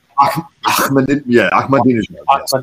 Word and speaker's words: Ahmadinejad: Ahmadinejad.